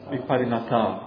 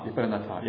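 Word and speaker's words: vipariṇata: Te atita niruddha Viparinata, is that right? Viparinata? Viparinata. Viparinata, yeah.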